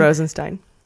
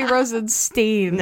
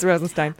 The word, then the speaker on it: Rosenstein: Ask what is the right way to say it again. – It's Rosenstein. – Oh, fuck. I was going to say Rosenstein. – Nope, it's Rosenstein.